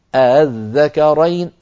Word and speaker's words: الذكرين: ويكون نطقها كما ذكرت لكم بين الهمزة المحققة والألف هكذا الذكرين الذكرين.